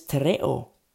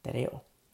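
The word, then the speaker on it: tereo (τηρέω): The word is tereo in the Greek, tereo. Tereo, I'll say it like that, tereo. I'm Latino, so tereo.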